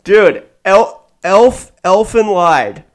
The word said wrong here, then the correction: Lied: Lead, yeah, it's pronounced lead, but yes.